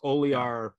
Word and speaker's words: Oliar: As we were discussing earlier, my last name is always mispronounced. It's Oliar. It used to have a J in it. Which would have, if it was O-L-E-J-A-R, then everybody would just say Ola Jar, because people in the United States don't know what to do with a J in the middle of a last name.